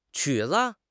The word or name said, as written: 曲子